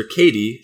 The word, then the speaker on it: Cady: So Lindsay Lohan's character Katie, C-A-D-Y, pronounced Katie as in Katie, but whatever, you know.